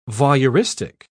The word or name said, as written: voyeuristic